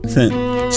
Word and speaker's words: scent: So say scent without the C. – Scent. – Say scent without the S. – Scent. – Right. Which one is silent? – The C. – Both of them is silent? – The C. – Okay, so the C is silent.